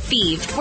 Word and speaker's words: Fives: Feed is spelled F-I-V-E-S, like the number, but pronounced Feve.